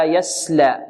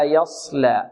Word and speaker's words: يصلى: نقول هكذا لا يس لا يسلى لا يسلى لا يصلى. انظر الآن وضحت الآن الصاد، وضح تفخيمه. لماذا؟ لأن أتينا بالضد، وبضدها ماذا؟ تتميز الأشياء. فعندما تنطق في مقابلها السين تقول لا يسلى لا يصلى، هنا وضح تفخيم الصاد، وضح ماذا؟